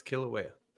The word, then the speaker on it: Kilauea: Not the same time, Kilauea. Is that how you pronounce it? The volcano? – Yes, Kilauea.